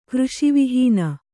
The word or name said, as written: ಕೃಷಿವಿಹೀನ